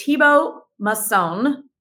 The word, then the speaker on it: Thibaut Masson: Our guest is Thibaut Masson. Did I say that right? – It's perfect.